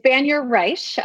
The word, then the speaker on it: Banyar-Reich: It's Banyar-Reich. I inherited the Reich. Okay. So no worries there.